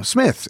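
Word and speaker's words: Psmith: like, you go, hello, my name is Rupert Smith. I was like, Smith, is it? No, Smith. There's a silent P.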